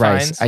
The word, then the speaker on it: Rhys: Is it Rice or Reese? – Reese? – I don't know. I would say Reese, Rice? But that's just me. – I guess that's the name. Reese, that's how you pronounce it on Discovery.